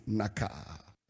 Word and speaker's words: nakah: The word smite in the Hebrew is the word nakah. Like the way you pronounce dakah. So nakah.